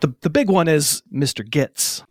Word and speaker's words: Gittes: And I have a point here, is that, so it's Gittes, it's spelled G-I-T-T-E-S, but I feel like it's pronounced six or seven different ways. – It really is. Thank you. – In the movie. – Everyone pronounces it differently. – Two ways. – Oh, so there's only two? – Mm-hmm. – I feel like it was like Gittes and... – Giddies, or like a Giddies? – Yeah, there's like a Giddies.